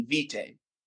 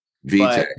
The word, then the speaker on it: Vitae: Yeah, Vita Records. – Vita. – I mean, it's Latin for life. For life records. – Oh, well, I dropped out of Latin class, so let me. – So technically the Latin pronunciation would be Vitae. – Vitae. – But, yeah, but we call it Vita.